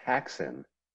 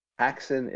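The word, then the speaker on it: Häxan: Carl, we are watching Haxan, Witchcraft Through the Ages, a 1922 silent movie from Denmark, from Switzerland, from over there.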